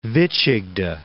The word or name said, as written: Vychegda